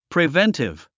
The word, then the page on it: preventive: privéntiv; プリベンティブ